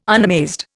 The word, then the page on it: unamazed: Indian